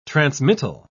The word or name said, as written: transmittal